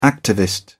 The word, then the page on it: activist: British English